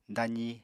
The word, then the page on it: dazi: apatani